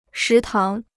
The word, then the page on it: 食堂: shí táng